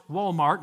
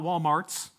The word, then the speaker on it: Walmart: And by the way, it's pronounced Walmart, not Walmarts.